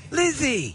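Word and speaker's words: Lisey: Lizzie! – And her late husband, Scott. I assume it's pronounced Lizzie. It's L-I-S-E-Y.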